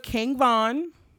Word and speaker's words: King Von: All right, let's get into King Von. I said it right. – You did.